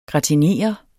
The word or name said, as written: gratinere